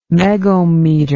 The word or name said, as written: megohmmeter